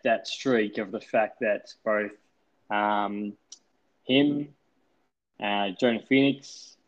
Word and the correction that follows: Joaquin Phoenix: and I probably butchered his name just then, and I think it's Joaquin Phoenix. – Yeah, Joaquin.